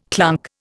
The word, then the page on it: clunk: Indian